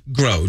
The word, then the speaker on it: Grow: Glow? – Grow. G-R-O-W.